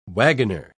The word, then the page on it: wagoner: British English